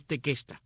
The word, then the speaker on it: Tequesta: Otros indios de esta área son los llamados tequesta o tecuesta, de acuerdo como lo mencionas, lo pronuncias al inglés o el español, pero es tequesta, tequesta con Q, T-E-Q-U-E-S-T-A, los tequesta, y los otros son los jaega.